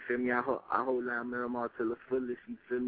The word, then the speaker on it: Miramar: Oh, I'm from Miramar.